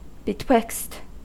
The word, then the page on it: betwixt: American English